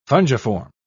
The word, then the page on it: fungiform: British English